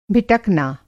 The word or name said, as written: भिटकना